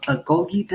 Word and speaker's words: Agoge: He's the co-founder, the CEO of Agoge. Did I say that right? – You did. You nailed it. Perfect.